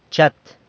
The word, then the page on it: chat: San Lucas Quiaviní